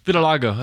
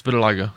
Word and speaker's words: Vitiligo: Vitiligo. That's how it's pronounced. Vitiligo.